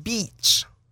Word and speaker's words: beach: If I don't know how I can delicately say this, but I had a student who would mispronounce the word beach, and she thought that you have to be careful. – That there was a swear word. She thought there was a swear word that sounds like the place where we go for recreation in the summer, and it could be very embarrassing. And so I was working with her on the pronunciation of that vowel.